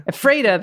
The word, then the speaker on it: Ephrata: If I'm ever back there and you hear me pronouncing, Ephrata the correct way, the Pacific Northwest way, don't come at me.